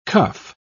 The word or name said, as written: cuff